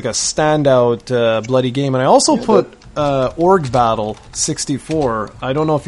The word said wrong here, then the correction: Ogre Battle: Isn't it pronounced Ogre Battle? – Yes, it is.